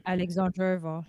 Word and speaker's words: Alexandrova: But yeah, Heather then played Ekaterina Alexandrova.